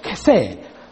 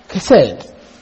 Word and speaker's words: chesed: Now the second word that is used is the Hebrew word chesed. Chesed. Chesed. C-H-E-S-E-D, or it can be spelled with H, but a hard I-H. Chesed or chesed.